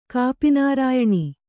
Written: ಕಾಪಿನಾರಾಯಣಿ

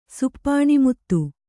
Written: ಸುಪ್ಪಾಣಿಮುತ್ತು

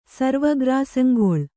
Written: ಸರ್ವಗ್ರಾಸಂಗೊಳ್

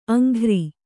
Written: ಅಂಗರ

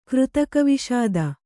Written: ಕೃತಕವಿಷಾದ

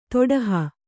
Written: ತೊಡಹ

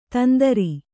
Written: ತಂದ್ರ

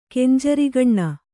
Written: ಕೆಂಜರಿಗಣ್ಣ

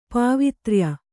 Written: ಪಾವಿತ್ರ್ಯ

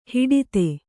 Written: ಹಟಾತ್ತು

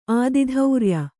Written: ಆದಿಧೌರ್ಯ

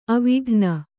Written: ಆವಿಕಣ್ಣು

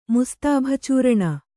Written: ಮುಸ್ತಾಭಚೂರಣ

